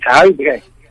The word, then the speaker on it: Huijbregts: Bas, welcome to Talking Pets, and I know you're live in the Netherlands, but I've got to ask you right now: How do you say your last name? – Yeah, that's a very difficult name to pronounce. My my last name uh, it's it's Huibre. – Abre. Am I close? – That's it.